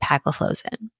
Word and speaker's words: empagliflozin: And the ones that have been studied are canagliflozin, dapagliflozin, and empagliflozin. And the way I remember it is like CDE. Those are the ones that have been studied so far. Canagliflozin, dapagliflozin, and empagliflozin.